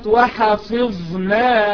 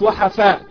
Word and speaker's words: وحفظناها: الغنة بمقدار حركتين، كذلك كلمة وحفظناها، مش وحفاء، وحفي، الفاء تحتها ايه؟ الفاء تحتها كسرة، وحفظناها.